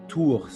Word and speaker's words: Tours: Y cuando ya decidí que iba a hacer un MBA, antes de irme del mi curso, estudié en Tours, se llama. Tours, Tours se escribe.